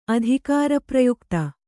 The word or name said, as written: ಅಧಿಕಾರಪ್ರಯುಕ್ತ